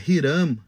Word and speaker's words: Hiram: E aí, pensando né, em Salomão buscando toda ajuda de fora, a gente vê como o, o rei Irã de Tiro, é, é, é, Hiram seria a pronúncia correta né, do hebraico,